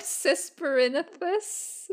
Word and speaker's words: Cisperinthus: I also liked Cisperinthus. Am I saying that right? – Yes, the spider who's just like jokes about eating everybody. He was amazing. – Cisperinthus is also from the Dungeons and Dragons game.